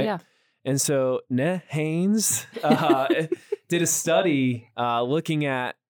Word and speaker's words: NHANES: And so how do you say it? Nanes? NHANES? Is that how you like to say it? – NHANES.